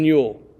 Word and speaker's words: Yuille: There's like 20-something episodes of it, but two of them are Stephen Yule. Y-U-I-L-L-E. Yeah, not how you would think you would spell it.